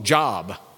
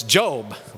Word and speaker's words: Job: And then there's a book in the Old Testament, you know, when you first come to Christ, you don't know how to pronounce it, and you call the book Job. And then you realize it's Job, right?